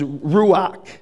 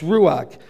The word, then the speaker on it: ruach: Now this is where I see his plan from the beginning, because the Hebrew word for spirit, and I won't pronounce it right, but I'll try, is ruach. Yeah? It's ruach.